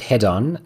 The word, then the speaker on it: Hedon: So the title I picked up is Hedon or Head On. How would you pronounce that? – Yeah, I call it Head On, and that would be extracted from the word hedonism. So Head On is which I would say hedonism.